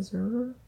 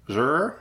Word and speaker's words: Zurer: But it's Ayelet Zurer. – Zer. – Zer. – Mm-hmm. You did it.